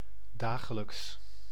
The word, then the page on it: dagelijks: [da.ɣǝ.lɛks]